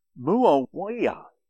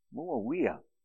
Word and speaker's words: Muawiyah: Well, the fifth caliph or caliphate was Muawiyah. Muawiyah. Pronounce that 18 times. M-U-A-W-I-Y-A-H. It's almost as hard to pronounce as some Korean names.